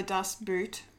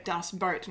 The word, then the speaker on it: Das Boot: Yeah, and it's also not pronounced like boat as you would in English, no. – But it's closer to English boat than it is to boot, if that makes sense.